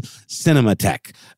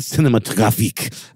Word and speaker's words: Cinematheque: Also mentioned the Egyptian, I pronounced it totally wrong, it's the American Cinematheque, not Cinematographique.